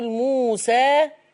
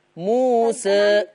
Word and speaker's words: موسى: أنا إحنا مش بنكتب كلمة موسى بالياء، بس بتتنطق ألف. هذه اسمها ذوات ياء، يعني ده مؤقتا. فذوات الياء لورش يا إما بيفتحها زي حفصة عادي جدا يقول موسى، يا إما بيقللها بيبتدي يميل بالفك بتاعه شوية موسى.